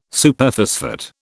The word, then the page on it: Superphosphate: British English